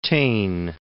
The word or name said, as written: Taine